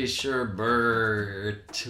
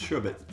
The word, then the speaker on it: sherbet: I did think that the word sherbet was actually sherbert with an R. – That's kind of the American pronunciation of it, but it's sherbet. – We put the R in there. It's not in the word.